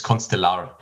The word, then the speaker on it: Constellar: Whatever you like it to be, but it's Constellar. That's at least what we say.